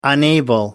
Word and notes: unable: American English

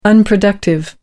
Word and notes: unproductive: American English

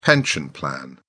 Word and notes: pension plan: British English

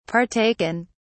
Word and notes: partaken: British English

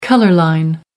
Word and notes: color line: American English

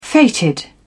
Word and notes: fated: British English

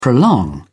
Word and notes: prolong: British English

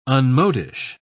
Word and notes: unmodish: British English

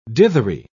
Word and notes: dithery: British English

